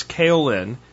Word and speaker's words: kaolin: He said kaolin clay. I've heard it said a bunch of different ways, but it's kaolin, K, and I'm going to spell it for you now, K-A-O-L-I-N.